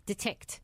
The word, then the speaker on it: Detickt: And it's spelled D-E-T-I-C-K-T. Detect. – Yes. – As in detect.